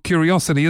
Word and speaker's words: Qriocity: It's spelled Q-R-I-O-C-I-T-Y, so Q-R-I-O and then city, which if you try and pronounce the Q as you normally would in a word, it kind of doesn't work out, but you have to say it as the letter.